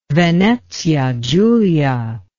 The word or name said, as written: Venezia Giulia